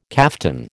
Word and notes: kaftan: American English

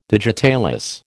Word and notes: digitalis: American English

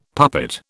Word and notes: puppet: British English

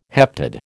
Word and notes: heptad: American English

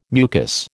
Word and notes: mucus: American English

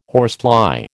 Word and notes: horsefly: American English

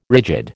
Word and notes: rigid: American English

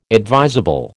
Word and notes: advisable: American English

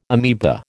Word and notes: amoeba: American English

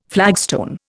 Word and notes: flagstone: Indian

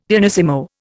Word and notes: pianissimo: Indian

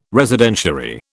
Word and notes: Residentiary: British English

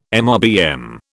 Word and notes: MRBM: British English

